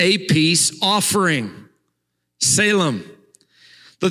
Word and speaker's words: Salem: The, the second word is the word uh, Salem, um, S E L E M. There's a city named after this. We spell that S A L E M, city of peace, Salem.